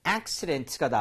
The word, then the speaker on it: accident: 얘는 강세 진짜 주의하셔야 돼요. 안 그러면 정말 촌스러운 발음이 나옵니다. 바로 accident가 나오는데 accident 앞쪽에 강세를 주게 되면 자, 잘 들어보시면 dent가 마치 른인 것처럼 힘이 빠져요.